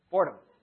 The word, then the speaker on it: Fordham: Fordham.